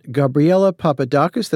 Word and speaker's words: Gabriela Papadakis: Now, the, the final, the pick three, we, we've, we've got Gabriela Papadakis, did I say that right? And Guillaume Cizeron. – That's correct.